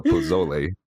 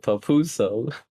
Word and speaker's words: pozole: Listen, Malik was having a hard time trying to understand how to say the proper word for pozole, which is, you know, it's a Mexican soup, but he kept calling it papuso.